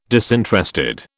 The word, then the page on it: disinterested: British English